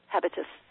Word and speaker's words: habitus: H-A-B-I-T-U-S. – Habitus.